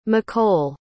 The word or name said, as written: mccoll